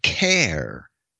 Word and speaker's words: Caer: Now, the word care, C A E R, spelled in C A E R, in Britain was a town, right? – It yeah, I was just going to say, town. it still is. It's, yeah, and it's pronounced car as well.